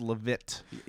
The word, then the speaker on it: Leavitt: It's pronounced Leavitt.